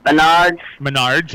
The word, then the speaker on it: Menards: But I got the paper from Bernard's, is that how you pronounce it? Menards, yeah.